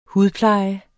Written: hudpleje

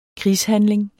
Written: krigshandling

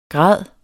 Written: grad

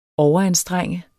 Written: OVERANSTRENG

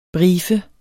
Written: briefe

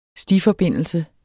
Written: stiforbindelse

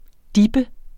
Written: dippe

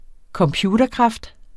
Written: computerkraft

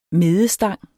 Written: medestang

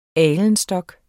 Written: alenstok

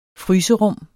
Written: fryserum

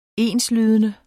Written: enslydende

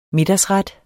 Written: middagsret

